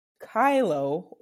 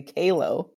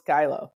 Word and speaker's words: Cailo: C-A-I-L-O. And is pronounced Kylo, or for my friends over in the UK, like to say Kalo, but I'm just glad you're talking about me, but it is pronounced Kylo.